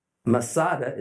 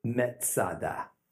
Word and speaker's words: Masada: Masada is merely a Roman way to pronounce the Hebrew word metzada.